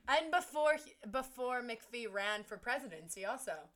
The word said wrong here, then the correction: McAfee: McAfee. I can never say that right.